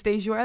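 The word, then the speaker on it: Dajour: It's Deshore Evans. That's D-A-J-O-U-R.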